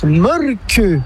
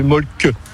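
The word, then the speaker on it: Mölkky: Ça s'écrit Molky, mais ça se prononce Molke. – Le Molke. – Plutôt un E qu'un E, mais pas un U. Donc ça fait Molke.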